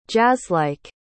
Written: jazzlike

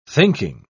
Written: thinking

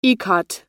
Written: ikat

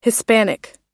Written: hispanic

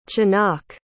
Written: chanoch

